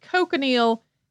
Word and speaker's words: cochineal: The cochineal is a scale insect that's native to Central and South America. Some people also pronounce it cochineal.